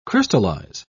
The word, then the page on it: crystallise: British English